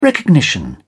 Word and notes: recognition: British English

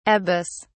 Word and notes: abas: British English

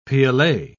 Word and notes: piolet: British English

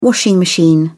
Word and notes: washing machine: British English

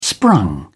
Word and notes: sprung: British English

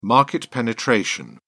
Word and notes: market penetration: British English

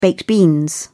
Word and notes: baked beans: British English

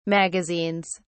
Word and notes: magazines: British English

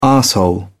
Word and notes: arsehole: British English

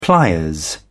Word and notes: pliers: British English

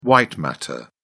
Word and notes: white matter: British English

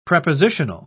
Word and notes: prepositional: British English

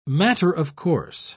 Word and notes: matter-of-course: British English

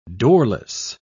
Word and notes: doorless: British English

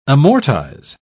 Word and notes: amortise: British English

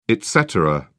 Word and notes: et cetera: British English

